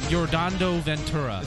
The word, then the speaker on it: Yordano Ventura: Yordando Ventura. – Is that how you say his name? – Yordando Ventura. – I was way off.